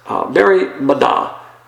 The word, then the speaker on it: merimna: That word is merimna. And that's merimna, I guess is a good way to pronounce it. Merimna. M-E-R-I-M-N-A, and that means cares, or anxieties, or worries, or heartaches, or troubles.